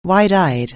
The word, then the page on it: wide-eyed: anglais